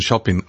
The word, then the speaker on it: Chopin: Also seitdem ich in Polen gewesen bin, ich war öfters in Polen, weiß ich ja, dass er in Polen Chopin ausgesprochen wird und nicht Chopin.